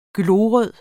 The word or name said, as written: glorød